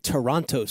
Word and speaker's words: Toronto: Okay, first of all, you pronounced that so badly. What are the Toronto stories? – The T dot. – You gotta forget the second T.